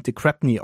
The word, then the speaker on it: Crespigny: Der volle Name lautet übrigens Richard Champion de Crépigny also Crespigny geschrieben, falls ihr das nur hört und nicht die Shownotes liest, aber da der Mann nun mal Australier ist, wird der Crapney, de Crapney ausgesprochen.